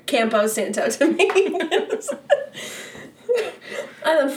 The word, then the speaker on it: Campo: The A is pronounced ah. Campo.